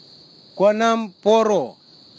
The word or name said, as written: kwanamporo